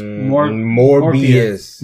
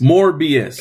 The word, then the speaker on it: Morbius: Morbius. – Morbius.